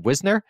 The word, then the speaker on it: Wisner: Wisner, I thought it's pronounced. Is it Wisner? – I think it's Wisner. W-I-S-N-E-R. – I think it's not W-I-E-S?